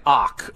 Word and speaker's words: Och: All right, it's Ryan Ock, O-C-H.